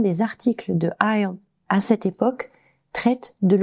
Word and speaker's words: ISLE: Donc IELE, ça s'écrit ISLE.